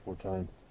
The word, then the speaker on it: Fortuyn: In Fortuyn, yeah. The homo. – Fortuyn, is that how you pronounce it? – Who nevertheless spoke up at, I've heard it pronounced different ways, but I think it's Fortuyn.